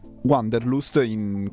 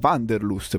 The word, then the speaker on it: Wanderlust: Sì, perché cioè le prime volte che io leggevo la parola, anzi la prima volta che mi sono approcciato al programma, ho detto per sbaglio a Francesca Milani Wanderlust con accento inglese. Sono stato cazziato proprio brutalmente. No, è una parola tedesca, si dice Wanderlust, per cui fate attenzione e significa appunto ossessione per i viaggi.